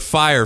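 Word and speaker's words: Fyre: Dude, there are two documentaries out right now about that fucking Fry Festival. – Is it Fry or Fire? – Or Fire. I don't know why I keep calling it Fry. Yes, it's the Fire Festival. It's spelled with a Y.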